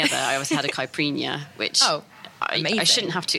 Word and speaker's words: caipirinha: So it's not pronounced Capriana, just so anyone knows that's very different, yes.